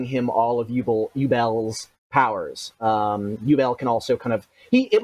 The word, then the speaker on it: Yubel: Yubel, apparently it's the correct way of pronouncing it,